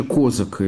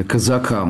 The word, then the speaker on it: Козака: С таким глобальным вопросом на наших глазах, к величайшему удивлению многих, становится личность Дмитрия Николаевича Козака или Казака. Он сам не знает, как следует ставить ударение в его фамилии. И так, и так.